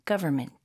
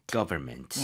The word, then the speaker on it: government: 이거 눈으로 보시면 중간에 N, M이 있어요. 근데 N은 거의 묵음이 돼요. 네, so government보다는 government. – Government.